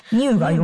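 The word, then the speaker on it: ニ ュー (ν): ニューが読めないな。そうそうそうそう、ね、 V って読んじゃうね。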